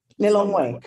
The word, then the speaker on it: Lilongwe: Lilongwe. – Lilongwe. Lilongwe.